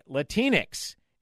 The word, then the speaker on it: Latinx: No one in America uses the word Latinx, except for a bunch of white progressives. It rhymes with Kleenex.